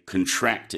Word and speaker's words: contract: It's different to the noun contract, or when we make a contract, but we pronounce that differently, and that's a different meaning altogether. When we say contract, it means to tighten, to make smaller.